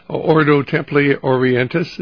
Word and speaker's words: Ordo Templi Orientis: is it Ordo Templi Orientis, is that it? – Yes, sir.